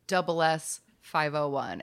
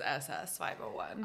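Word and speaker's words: SS501: And it is double S. – It is pronounced double S. Okay, I was just reading it as SS 501. – I know, I'm sure I have said that on the show before. I have said SS 501.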